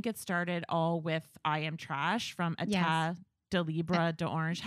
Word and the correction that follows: Etat Libre d'Orange: How do you say that? Yeah. – Etat Libre d'Orange. – Mm-hmm. Perfect.